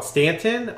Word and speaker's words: Staunton: Beth and I took a fun trip yesterday, just a day trip on the weekend, to a city called Stanton. It's spelled S-T-A-U-N-T-O-N.